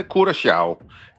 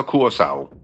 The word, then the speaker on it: Curaçao: Ja, hvis du må tage det helt korrekt, så siger det, hedder Curaçao. Det der C med den lille krølle under, det får sådan en sjav-lyd, men langt de fleste, de udtaler blot som et S, C, så Curaçao.